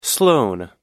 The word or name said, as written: sloane